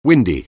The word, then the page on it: windy: anglais